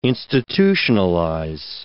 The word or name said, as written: institutionalize